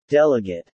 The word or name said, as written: delegate